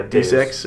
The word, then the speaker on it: Deus Ex: Deus Ex or is that how you pronounce it? I don't know. D E U S. – Yeah, Deus. Ex or Deus Ex.